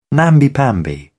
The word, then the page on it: namby pamby: British English